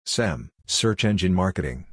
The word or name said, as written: SEM (Search Engine Marketing)